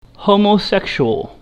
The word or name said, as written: homosexual